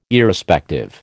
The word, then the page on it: Irrespective: American English